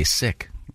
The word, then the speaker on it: Sikh: Okay, you know Sikh is the Americanized pronunciation. It's incorrect. – No, it's not. Not if it's Americanized. – Sikh is correct. – We're Americans. – Sikh. Yeah. – Okay. – There you go. He listens to NPR where they're terrified to accidentally say something. – You say Paris or Paris? – I say Paris, but... – Well... – The New York-based Sikh Coalition says the correct pronunciation is S-I-K, Sikh.